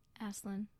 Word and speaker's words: Asselin: Asselin.